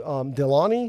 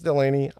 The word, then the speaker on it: Delaunay: You can still use the legacy pixel fill algorithm, but the new Delaunay, I'm going to say it's Delaunay, could be pronounced Delaney, I don't know, D-E-L-A-U-N-A-Y, I'm going to say Delaunay, even if I get it wrong, someone will, I'm sure, will correct me.